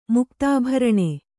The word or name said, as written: ಮುಕ್ತಾಭರಣ